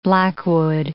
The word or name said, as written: blackwood